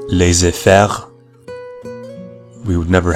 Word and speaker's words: laissez faire: If you noticed, I pronounced laissez faire the English way, laissez faire. I'm not an expert in French, although it is a language that I'm currently learning, but the French pronunciation would be laissez faire, laissez faire. In English, we would pronounce it as laissez faire.